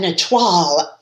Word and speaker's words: étoile: but an étoile.